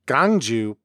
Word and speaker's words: Gwangju: The biggest city in South Jeolla province, Gwangju, is often pronounced gangju by Jeolla speakers.